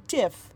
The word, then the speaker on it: GIF: Apparently, according to the person that quote unquote pioneered the form, it's supposed to be pronounced Jif, but I will fight him because it's graphic interface format.